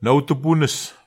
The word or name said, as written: naotupunus